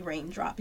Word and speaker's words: Reigndrop: So people just started calling me Reigndrop because I was little. – Yes. – And I just decided to spell it R-E-I-G-N instead of R-A-I-N because it had a more meaning.